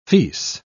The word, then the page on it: fils: British English